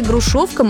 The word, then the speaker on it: Грушовка: Есть такой старинный русский сорт народной селекции «Грушовка московская». Ударение на втором слоге.